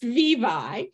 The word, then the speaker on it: Vevye: The second one is Vevye. That's how it's pronounced, Vevye.